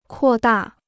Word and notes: 扩大: kuò dà

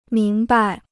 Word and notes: 明白: míng bai

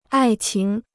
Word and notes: 爱情: ài qíng

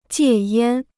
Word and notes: 戒烟: jiè yān

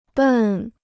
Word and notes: 笨: bèn

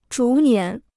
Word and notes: 逐年: zhú nián

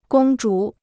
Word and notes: 公主: gōng zhǔ

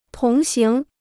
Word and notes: 同行: tóng háng

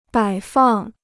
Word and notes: 摆放: bǎi fàng

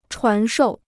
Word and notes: 传授: chuán shòu

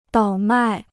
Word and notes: 倒卖: dǎo mài

